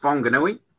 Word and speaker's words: Whanganui: I've come from a place in the North Island on the west coast called Whanganui, which is now pronounced Whanganui.